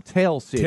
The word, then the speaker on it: Tell City: She said, how do they spell it? – Tell, T-E-L-L. – Okay. – Yeah. Oh, and she said, no, T as in Tom, Tell City.